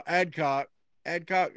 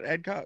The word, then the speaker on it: Adcock: Adcock, am I saying that right? Adcock? – Yeah.